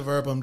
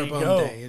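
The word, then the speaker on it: Verbum Dei: And no shade to Verbum Day. Let's get there. The pronunciation of their name right. It's Latin, and it's verbum. – Verbum. – Yeah, there Verbum Day.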